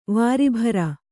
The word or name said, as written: ವಾರಿಭರ